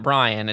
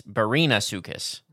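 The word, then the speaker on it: Barinasuchus: It's not Brian. It's Barina Sukus. – Barina? Is that how you say it? – Yeah. B-A-R-I-N.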